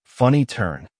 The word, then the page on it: funny turn: American English